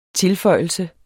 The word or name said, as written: tilføjelse